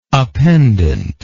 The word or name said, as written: appendency